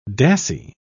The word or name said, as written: dassie